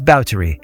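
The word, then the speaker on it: Báthory: And just a note, if you've ever read about Elizabeth, you might have assumed her surname, spelled B-A-T-H-O-R-Y, is pronounced Bathory, but the proper period correct pronunciation is Bowdry.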